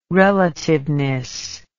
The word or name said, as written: relativeness